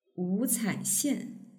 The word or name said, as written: wucaixian